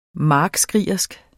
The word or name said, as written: markskrigerisk